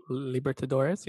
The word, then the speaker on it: Libertadores: Libertadores, yeah.